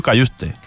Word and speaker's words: Cajuste: Los mismos que tiene el otro, que ya también está aquí en Málaga, Jens Cajuste. ¿Este lo he pronunciado bien? No. – Cayuste, Cayusta, ya ahí como quiera, porque escuchábamos a algún aficionado del Nápoles que decían Cayuste, Cayust, entonces, en principio, Cayuste.